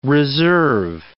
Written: reserve

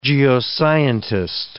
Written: geoscientist

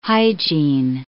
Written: hygiene

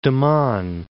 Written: Daman

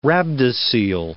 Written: rhabdocoele

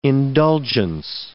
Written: indulgence

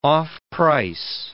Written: off-price

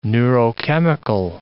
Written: neurochemical

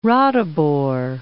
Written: Ratibor

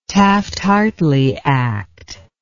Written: taft hartley act

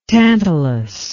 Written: tantalous

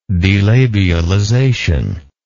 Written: delabialization

